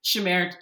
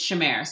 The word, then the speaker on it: Shamir: Shamir, it's Shamir. So it's one of those ones that seems more complicated than it is. But yeah, it's like Sham-air, like exactly as it's spelled.